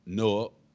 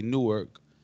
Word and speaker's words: Newark: Usually outsiders say Newark, and people from Newark say Newark. Am I right, Ness? – Outside of the city, they pronounce it like New York. It's like Newark.